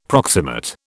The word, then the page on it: proximate: British English